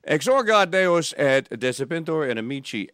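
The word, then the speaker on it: exsurgat: Exorget or exorgat? – Okay, I was confused as a hard G or a soft G. – Is it followed by an A or an E? – A. – Oh, then it's exorgat.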